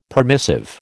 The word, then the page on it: permissive: American English